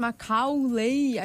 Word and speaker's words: Macaulay: Então foi uma falha de pronúncia do Capu. Porque se você tivesse falado Macaulay, a gente teria falado.